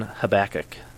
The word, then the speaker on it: Habakkuk: So it's Amos and then Habakkuk. – That's the correct pronunciation? – Yep.